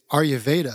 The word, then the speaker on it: Ayurveda: It's Ayurveda.